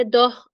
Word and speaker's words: داه: تو فرهنگ عمیدم نوشته که معنی داه همون عدد دهه، از رودکی یه مثال داده یا شاید مثال که رودکی هم با تلفظ داه با جایگاه قافیش کرده. اخترانند آسمانشان جایگاه، هفت تابنده روان در دو و داه. جایگاه با داه، دو و داه هم همین 12 میشه دیگه.